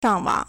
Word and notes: 上网: shàngwǎng

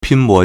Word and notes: 拼搏: pīnbó

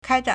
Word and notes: 开展: kāizhǎn

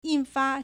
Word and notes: 印发: 印發; yìnfā

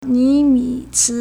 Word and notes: 尼米兹: nímǐzī

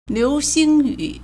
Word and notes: 流星雨: liúxīng yǔ

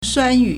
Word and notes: 酸雨: suānyǔ

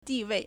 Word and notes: 地位: dìwèi

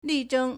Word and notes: 力争: lìzhēng

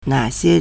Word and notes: 哪些: nǎxiē

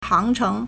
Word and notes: 航程: hángchéng

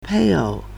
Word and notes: 配偶: pèiǒu

